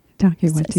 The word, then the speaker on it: Taika Waititi: It's also um, one of um, uh, let me make sure I'm pronouncing Ta- Taiki Wa Watiti Watiti's. – Taika Waititi.